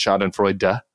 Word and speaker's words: Schadenfreude: I guess we have some German aficionados who say that every single person at the Bulwark mispronounces Schadenfreude, and it's Schadenfreude. We have to pronounce the E.